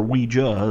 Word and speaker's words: Ouija: The first actual Ouija board, or Ouija is how it's pronounced, board, actually was introduced into society in 1890.